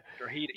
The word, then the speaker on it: Drogheda: Drogheda. You don't pronounce the G.